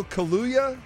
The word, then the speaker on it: Kaluuya: Daniel Kaluuya. Am I saying his name right? – Uh-huh.